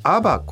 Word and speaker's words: ABA: 宇井さんは今、株式会社アバ、これアルファベットで ABA と書いて、はい、アバという会社を設立されたわけですが、はい、このまずアバっていう宇井さんが作られたベンチャーはどういうことをやられてるんですか。